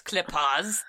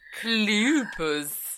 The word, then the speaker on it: Kleypas: But I think it's Kleypas. – Kleypas. I have no idea how to say it. – It's Kleypas.